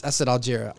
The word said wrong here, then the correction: Allegra: I'm sorry. – Is it a you Snow Allegra? Hold on. I'm just going. You know, I'm just going to find an interview with where they say her name. – Yeah, it's Allegra. It's Allegra.